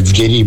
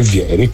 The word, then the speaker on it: Vieri: C'è un altro brasiliano storicamente importante che ha un cognome italiano e si pronuncia senza accento, quindi non è Vieri ma è Vieri, come non era Altafini ma Altafini, cioè José Altafini, cognome italiano.